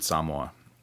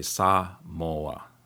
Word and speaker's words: Samoa: They don't say Samoa. They always say Samoa. Actually, it's moi, more than moa. So it's Samoa and Samoan people.